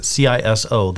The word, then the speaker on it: CISO: I think at least that's how I pronounce it. CISO.